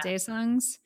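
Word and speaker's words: Daesang: Daesangs, is that how you say it? Yeah. Daesangs.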